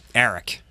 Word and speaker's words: Aric: My real name is spelled A-R-I-C, but people here in the Midwest can't pronounce that. – They would call Arc. – Yeah. And maybe different tongues from different parts of the country. – Arlac. – No. – I don't know. – But I should call my dad. My dad and my brother are the only two people who say it right. Eric.